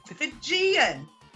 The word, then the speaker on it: Fijian: Not Fugazi, it's Fijian. – Fijian. That's how you pronounce it. If it's from Fiji, it's Fijian. – I thought it was Fijian.